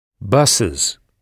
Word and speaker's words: buses: Comment se prononce son pluriel? – Buses. – Buses, oui, avec le son is.